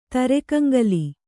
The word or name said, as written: ತರೆಕಂಗಲಿ